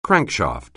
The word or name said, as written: crankshaft